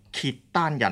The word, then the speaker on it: 契丹: 契丹人，或者有人读作契丹人，在首领耶律阿保机的领导之下日渐强盛，契丹骑兵时常入侵瀛州、平州，甚至围攻幽州，威胁晋国的后方。